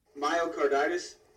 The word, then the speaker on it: myocarditis: Myocarditis.